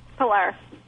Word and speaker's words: Pilar: Is it pronounced Pilar? – Pilar.